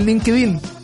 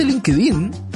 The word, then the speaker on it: LinkedIn: Esto no es LinkedIn, que el otro día me enteré que no se dice LinkedIn. Es LinkedIn. LinkedIn.